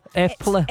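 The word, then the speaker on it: Eple: Epla. – It's apple, but it's... – It means apple in Norwegian.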